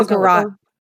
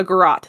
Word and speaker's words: garrote: A nearby wire was found, and authorities actually believe that that was used as a, I don't know if I'm saying this correctly either, but a garrote? – Oh, a garrote. – A garrote, thank you.